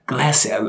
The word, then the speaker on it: glacier: A glacier. A glacier. – Yeah. You don't quite say it like Bear Grylls, but close. Glacier. He says it glacier. – Right. – Which sounds so awesome.